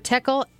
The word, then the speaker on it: Techel: We are back with Barbara Techel.